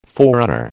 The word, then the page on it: forerunner: American English